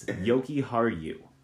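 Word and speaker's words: Jokiharju: And by the way, it's Yoki Haru. It's two Y sounds on the J's.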